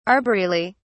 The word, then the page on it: arboreally: British English